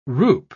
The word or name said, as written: roup